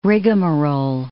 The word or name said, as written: rigamarole